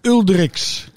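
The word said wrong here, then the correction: Uldriķis: Ja. Uldrikis. Ja. Ik wou het bijna ja, in... Fries uitspreken.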